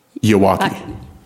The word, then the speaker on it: Yawakie: Would you tell me how to spell your name again? – It's spelled Y-A-W-A-K-I-E. – And say it with me. – Iwaki.